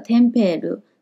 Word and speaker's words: tempero: 好了，tempero，tempero，好，那这个 tempero 在名词指的是调味料，并没有油炸的意思。